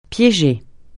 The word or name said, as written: piéger